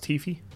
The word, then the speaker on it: Hi-Fi: It's pronounced Hi-Fi.